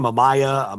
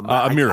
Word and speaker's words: Amira: And what's her name? Amaya? – Amira.